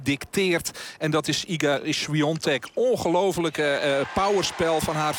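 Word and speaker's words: Świątek: Het is Swiatek, want zo spreek je dat op zijn Pools uit. Iga Swiatek, die 6-2 en 3-0 voorstaat.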